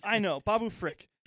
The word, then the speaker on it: Frik: Babu Freak. – Babu Freak. Okay. – Babu Freak. – It wasn't Frick. I thought. – Frick. It's spelled like that, though. – Okay. – Yeah. It is spelled F-R-I-K, because I just found it, too.